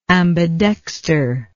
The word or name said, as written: ambidexter